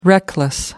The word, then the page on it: reckless: American English